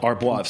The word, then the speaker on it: Arbois: Arbois.